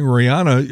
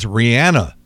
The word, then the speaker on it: Rihanna: And I say Rihanna because that is the correct pronunciation. If you're saying Rihanna, you're saying it wrong. You have to say it like Rhiannon. So it's Rihanna, 36, Trevor Noah, 40, Justin Verlander, who's pitched for the Mets, Detroit, Houston, won a World Series with the Astros.